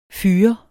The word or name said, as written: fyr